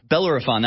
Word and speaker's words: Bellerophon: Let's go now to Belliferon in Wisconsin. Alien Disclosure News and Mask Society, if I'm not mistaken, and I'm not because I pulled it up on the encyclopedia, Bellerophon, that's how you pronounce it, the hero who battled and killed the Chimera Monster.